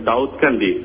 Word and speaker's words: দাউদকান্দি: এটা হচ্ছে দাউকান্দি সোনাতলি গ্রাম, রাইট? – না স্যার, এটা হচ্ছে দাউদকান্দি,